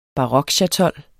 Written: barokchatol

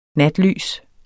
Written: natlys

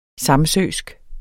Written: samsøsk

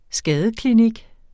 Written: skadeklinik

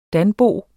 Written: danbo

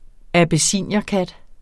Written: abyssinierkat

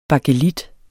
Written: bakelit

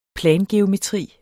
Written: plangeometri

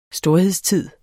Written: storhedstid

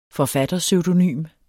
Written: forfatterpseudonym

